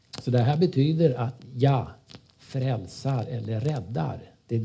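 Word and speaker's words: ja (יה): Men de två första bokstäverna, det är ja, uttalar man det.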